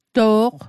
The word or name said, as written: to'q